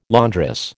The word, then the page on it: laundress: American English